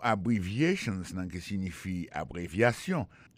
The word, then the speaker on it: abbreviations: Abbreviations, accent tonique là, tombé sous troisième syllabe là. Nan mot ça, gen quatre syllabes. C'est A, bon syllabe, bri, c'est deuxième syllabe là, vie, c'est troisième syllabe là, chan, c'est quatrième syllabe là.